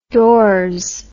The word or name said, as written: doors